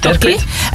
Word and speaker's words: Terquit: Jouw bedrijf heet Terquit, zeg ik het goed zo? – Ja, klopt. Terquit.